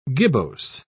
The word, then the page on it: gibbose: British English